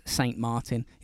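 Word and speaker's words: Saint-Martin: It is Saint Martin. It's not some like nice accent.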